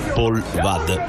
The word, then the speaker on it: Paul Wade: Dus dan zullen we het maar op zijn Frans uitspreken. Omdat het, zoals het geschreven is, W-A-D-E. Neigt om Engels uitgesproken te worden. Maar mijn Franse collega's geven aan dat het wel degelijk op zijn Frans moet uitgesproken worden.